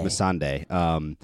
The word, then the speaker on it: Missandei: The scene with Tyrion and those two, and Grey Worm and Missandei, is that how you pronounce it? – Missandei.